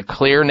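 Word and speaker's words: Xlear: And Clear is spelled with an X, X-L-E-A-R.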